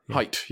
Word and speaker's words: Haidt: So some people mispronounce his name too. They say Haidt. – Haidt, yes. – That's Haidt. – Height, yes.